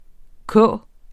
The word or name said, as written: k